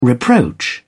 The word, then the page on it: reproach: British English